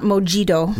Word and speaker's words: mojito: Yeah, mojito. – It is not mojito. – Right, right. Yeah, this is a Spanish word. So the J is like huh.